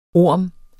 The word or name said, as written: orm